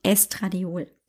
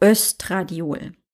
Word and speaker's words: Östradiol: Und du hast mich wahrscheinlich auch im Podcast eher mehr sprechen hören vom Estradiol oder auch Östradiol.